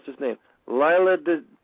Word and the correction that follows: Leela: Is it Desi or Desi? – You got it's Desi, but it's Leela. Philomena Leela. – Philomena Leela Desi.